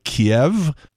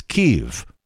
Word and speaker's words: Kyiv: So now it turns out that the Russian pronunciation of that city is more like Kiev. And in a way, I guess it sort of shows what side you're on, depending on how you pronounce it, because the Ukrainian pronunciation is Kyiv. So if you hear anybody say Kiev, they're on the wrong side.